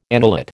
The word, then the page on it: annulate: American English